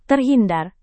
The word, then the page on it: terhindar: indonesian